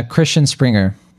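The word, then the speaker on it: Christian Sprenger: for instance, the film is shot by Christian Spellinger. I don't know if I'm pronouncing that right, but it's a Christian Springer. – Yeah, Christian Springer,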